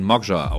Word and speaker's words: Magijar: Genau, M-A-G-I-J-A-R, glaube ich. Ach so, glaub ich so. Okay, ja, okay. Genau. Und da wurde immer berichtigt, ja, das spricht man Mogja aus oder so ungefähr, ne?